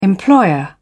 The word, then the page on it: employer: British English